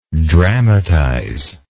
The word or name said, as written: dramatizer